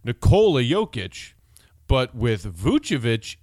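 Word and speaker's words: Vučević: Not Vukovic, not Vucevic, Vucevic. And it's Nikola, emphasis on the knee, first name.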